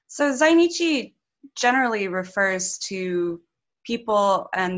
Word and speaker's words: Zainichi: Like, is it Zainichi? – Zainichi, yeah.